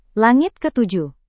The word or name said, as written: langit ketujuh